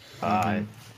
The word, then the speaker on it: Gojira: So Gojira is the... I don't know about the name, but I think it's the correct pronunciation. – Yeah, it's in the original Japanese. Godzilla. That was Gojira.